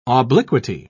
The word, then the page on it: obliquity: British English